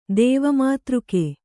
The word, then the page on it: ದೇವಮಾತೃಕ: kannada